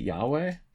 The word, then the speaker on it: Yahweh: Is it Yahweh? – That's how you say it, right? – Yeah.